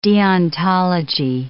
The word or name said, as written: deontology